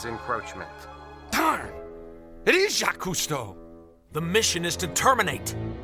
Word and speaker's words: Cousteau: Cousteau.